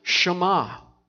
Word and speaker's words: Shema: This is called the Shema because of the first word in Hebrew that God had Moses write this out is pronounced Shema.